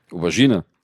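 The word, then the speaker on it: René Auberjonois: Unter anderem eben auch der Erwähnung, dass ihr Mann Yu, der hier gespielt wird von René Aubergenois. – Aubergine. – Ja. – Wird er ausgesprochen.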